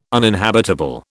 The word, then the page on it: uninhabitable: British English